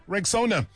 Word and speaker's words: Rexona: It's pronounced Rexona.